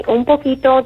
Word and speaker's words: un poquito: Well, I just wanted to let you know that, yes, you said it right. Un poquito does mean a little bit, and I thought that was great.